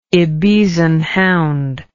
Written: ibizan hound

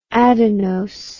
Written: adenose

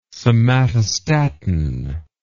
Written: somatostatin

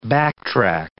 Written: backtrack

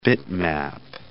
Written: bitmap

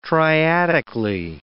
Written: triadically

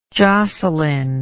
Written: jocelyn

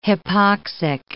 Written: hypoxic

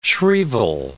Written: shrieval

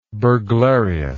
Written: burglariously

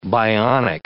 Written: bionic

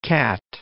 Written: Catt

